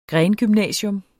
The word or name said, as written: grengymnasium